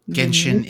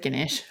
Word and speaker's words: Genshin: Genshin Impact. – Genshin. I said Ganesh.